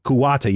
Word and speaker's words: Kuwata: Kuwata.